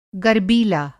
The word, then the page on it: गरबीला: Hindi